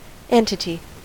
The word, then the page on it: entity: American English